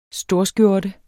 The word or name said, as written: storskjorte